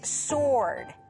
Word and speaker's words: sword: And the third word is sword. Sword. 네, SW지만 W 친구들 발음이 안 납니다. Sword이야. Sword가 아니고요. Sword. 네, 검입니다.